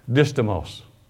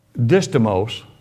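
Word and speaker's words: distomos: But it's D-I-S-T-O-M-O-S, distimos. And it's pronounced many different ways, but I don't care how you pronounce it. Do what you want to with it. Distimos.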